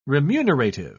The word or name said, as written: remunerative